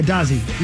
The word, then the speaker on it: Dozzy: D-O-Z-Z-I-E. Dazzy. – How do you say it? – Or maybe Dazzy? – Y. Yeah, Dazzy. D-O-Z-Z-Y.